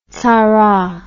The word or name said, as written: tzara